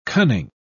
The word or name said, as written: cunning